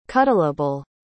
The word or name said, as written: cuddleable